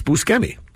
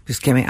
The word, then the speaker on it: Buscemi: Oh, by the way, incidentally, thanks to the new Seth Rogen show on Apple called The Studio, I now know his name is actually pronounced Buscemi. – Buscemi.